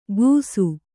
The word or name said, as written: ಗೂಸು